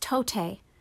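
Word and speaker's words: tote (τότε): In the Greek, this word is pronounced tote, and is best translated as "then" or "at that time."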